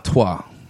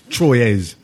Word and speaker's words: Troyes: Trois. – Is that how you say it? – Yeah. – Trois. – Yeah. – Okay. It's spelt Troyes. – Yeah, it is. Troyes.